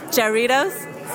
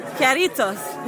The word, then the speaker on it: Jarritos: J-A-R-R-I-T-O-S. – Jarritos? – See, that's what I said.